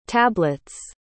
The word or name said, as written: tablets